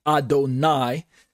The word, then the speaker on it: Adonai: And they, they are definitely pronounced differently if you can hear them, Adoni, Adonai.